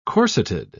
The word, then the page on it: corseted: British English